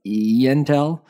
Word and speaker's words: Entel: They announced expansion into Chile and Peru through its intended acquisition of 4 data centers of Entel. It's spelled like Intel, but E-Entel.